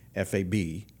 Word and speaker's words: Sterifab: Yeah, Sterifab is just like it's spelled. I mean, it sounds. It's Sterifab.